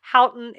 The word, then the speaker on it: Houghton: What I will say is Katharine Houghton. It's H-O-U-G-H-T-O-N. – I was guessing that's how it was spelled. And I was thinking. – Houghton? – That sounds like worse.